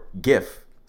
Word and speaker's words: GIF: Graphic doesn't start with a fucking soft G, all right? It starts with a hard G. Therefore, GIF starts with a hard G.